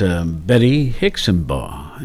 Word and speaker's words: Betty Hixenbaugh: Our guest today is Betty Hixenbaugh.